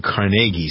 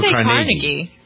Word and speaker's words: Carnegie: Some people pronounce it Carnegie, some people Carnegie. – Carnegie. Carnegie's very fancy.